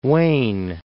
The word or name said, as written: Fort Wayne